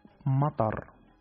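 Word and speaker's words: matar (مطر): Se pronuncia matar.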